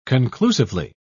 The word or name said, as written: conclusively